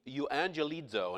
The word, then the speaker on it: euangelizo: The Greek term for good tidings is a word that's pronounced, I think, euangelizo, and it means to announce good news, okay?